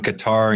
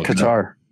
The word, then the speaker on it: Qatar: I like Qatar. I might just stick with that. – Qatar? Emphasis on the second A? – Yeah. It's going to be hard for me to say Qatar because that's how the English say it.